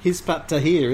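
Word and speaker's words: Hizb ut-Tahrir: How do you pronounce it? – Hizbut Tahrir, isn't it? – Thank you.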